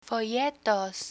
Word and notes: folletos: Spanish

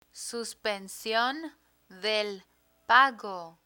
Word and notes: suspension del pago: Spanish

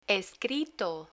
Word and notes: escrito: Spanish

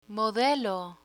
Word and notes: modelo: Spanish

